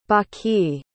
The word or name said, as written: baki